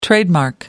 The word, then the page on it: trademark: American English